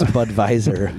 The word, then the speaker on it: Budweiser: Budweiser. – I believe you pronounce the W as a V. – Yeah, it's Budweiser.